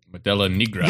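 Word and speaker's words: Modelo Negra: But anyway, I'm drinking on a Modelo Nigra. That's how you say it. Modelo Nigra.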